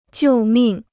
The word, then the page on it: 救命: jiù mìng